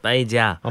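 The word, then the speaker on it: paella: 근데 이 아르헨티나 사람들은 이그 빠이아이 이거 야가 제이인데 철자가 빠이자 어, 빠이자라고 음. – 어 그래서 일부러 스페인 사람들과 다르게 발음을 해서 음.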